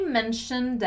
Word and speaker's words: mentioned: And then the past has just a D sound. Mentioned, mentioned, mentioned.